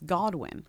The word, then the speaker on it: Godwin: She's not more famous than Stranger Things, but I feel like more people know Hannah Goodwin's name than Noah Schnapp's. – No. Also, you're saying it wrong. It's Godwin.